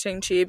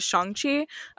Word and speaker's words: Shang-Chi: By the way, I, I know this now because of the spot, but I keep saying it Shang-Chi because I've gotten so used to saying it. But I think the official pr- official pronunciation is Shang-Chi uh, because in the spot, mm. whoever's narrating says Shang-Chi.